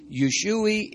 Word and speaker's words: Usui: It's spelled M-I-K-A-O. M-I-K-A-O, Mikao. Yeshui is U-S-U-I.